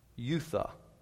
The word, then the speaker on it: oida: Youtha, spelled O-I-D-A, pronounced "youtha."